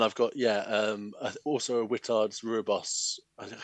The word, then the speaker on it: Rooibos: And there was someone sort of moderately early on who very, very confidently pronounced it Rooibos. And I've gone with that ever since. – Sure. Redbush, that. – Yeah. – That's what I usually just default to Redbush because it saves the problem. Rooibos.